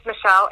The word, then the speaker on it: Michale: Yeah, so my name is Michale, M-I-C-H-A-L-E, and the last name is Hart, H-A-R-T-T-E.